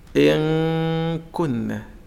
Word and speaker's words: إن كنا: كيف أنطق إن كنا؟ شوف الغنة إن أدي النون والكاف أك، إن كنا إن كنا، وليست إن إن في مسافة عند النون قبل أن تصل إلى الكاف.